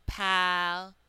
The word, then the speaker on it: palace: The second one, palace. So there's two A's in palace, just like in atlas. But in the first A, in the first syllable that was stressed, you heard the whole A sound, pal-us. A is reduced to the schwa sound in the second unstressed syllable.